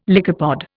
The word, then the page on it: lycopod: Indian